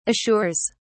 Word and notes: assures: British English

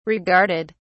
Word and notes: regarded: British English

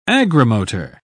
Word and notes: agrimotor: British English

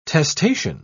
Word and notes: testacean: British English

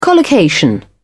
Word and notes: collocation: British English

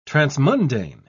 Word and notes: transmundane: British English

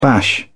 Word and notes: bash: British English

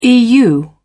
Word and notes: eu: British English

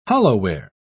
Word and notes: hollowware: British English

